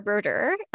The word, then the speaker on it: Brodeur: So I'm Lara Broder. So that's how you pronounce my last name, little French too for you there.